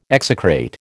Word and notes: execrate: American English

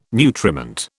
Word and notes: nutriment: British English

